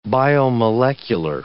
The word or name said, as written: biomolecular